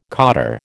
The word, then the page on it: cottar: American English